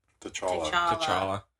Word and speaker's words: T'Challa: And so the plot synopsis is T'Challa... Is that how you say it? – T'Challa.